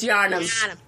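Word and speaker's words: Yharnam: And you are then transported to this town called Yarmen, which is considered... – You gotta pronounce it like it's a Massachusetts city. Yarnum. It's Yarnum.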